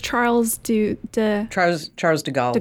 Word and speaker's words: Charles de Gaulle: Oh, I don't know how to say this. Paris, Charles de Gaulle. – De Gaulle, okay.